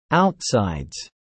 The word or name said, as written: outsides